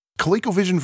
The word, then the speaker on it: ColecoVision: ColecoVision version, that's the first time I think I ever said that right.